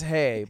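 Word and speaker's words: Hej: Hedge. – And they just say, Hedge? – That's H-E-J. – It's pronounced hey, but... – They don't say hedge.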